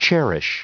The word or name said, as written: cherish